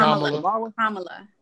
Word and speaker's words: Kamala: How you say her name? Kamala Harris? – Kamala. – Kamala? Oh, okay, my bad.